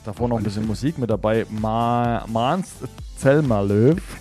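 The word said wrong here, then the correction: Måns Zelmerlöw: Mans Zelmerlöw.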